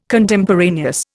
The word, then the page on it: Contemporaneous: Indian